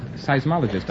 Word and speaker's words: seismologist: Now, how do you pronounce it? – Seismologist, I believe. Seismologist.